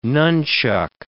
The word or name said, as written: nunchaku